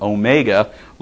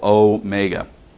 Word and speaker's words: Omega: Okay, and the very last letter in Greek, Omega, looks like a W in English, but it's the O sound. It's what we would call the long O sound.